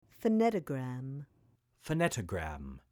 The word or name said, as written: Phonetogram